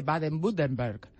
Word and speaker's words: Baden-Württemberg: concretamente al estado de Baden-Württemberg.